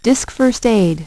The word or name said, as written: Disk First Aid